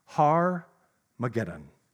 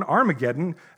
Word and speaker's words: Armageddon: But I'm actually mispronouncing it. It's actually Har-mageddon. Now, this has become popularly known, Armageddon, as the name for a battlefield in symbolic war.